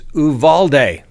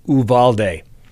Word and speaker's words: Uvalde: All right, aficionados, Uvalde. That's the Spanish pronunciation. That's right. I've got this from reliable sources. It's pronounced Uvalde.